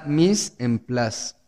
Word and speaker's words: Mise en place: Eh, se llama Miss en Place. Se escribe Mise en Place.